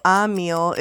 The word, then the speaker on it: Emil: Emil. – With an A, even though it's spelled with an E.